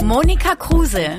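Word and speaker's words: Monika Kruse: Cruz. No, para, para, para, para, para, para. Re, por favor, eh, pronunciamelo en alemán. – Mónica Kruse.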